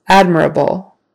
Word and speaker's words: admirable: Admirable. Now the emphasis is on the first syllable.